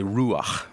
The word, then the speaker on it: ruach: So whenever we see that God breathes life, that word there for breathing is this Hebrew word ruach that's really fun to say that I don't say right, but you could try to say it right. So say ruach, right?